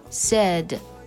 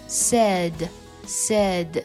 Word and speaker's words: said: 最后我们来说这个单词的动词过去式和过去分词，它读作 said。 AI 在这里呢发的也是短音 a，虽然这个单词的书写和音标的书写 i 很相似，但它发的就是短音 a，它不会发别的音。 said 这个单词的英式音标和美式音标书写也不同，发音是一样的。来读一下这个单词 said said，一定要和 side side 这个单词进行区分。